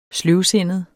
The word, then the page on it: sløvsindet: ˈsløwˌsenəð; ˈsløwˌsenˀəð